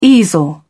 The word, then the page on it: easel: British English